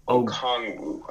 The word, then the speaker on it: Okongwu: Center Onyeka Onwuegbu. Did I butcher that? – Okongwu.